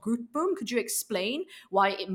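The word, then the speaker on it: Grootboom: Subramani is quite famous, but South Africans pronounce it Groepboom.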